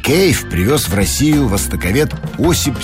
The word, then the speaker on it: кейф: В слове «кайф» английского языка нет даже близко. И вообще правильно говорить «кейф».